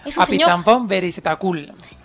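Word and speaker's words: Apichatpong Weerasethakul: dirigida por el señor Apichatpong Verasetakul. – Apichatpong Verasetakul.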